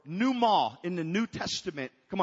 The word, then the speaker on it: pneuma: It means, you don't pronounce the P, but it's called pneuma. That's an N.